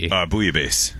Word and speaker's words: Bouillabaisse: Bouillabaisse.